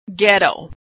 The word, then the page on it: ghetto: g